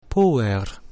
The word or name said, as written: puer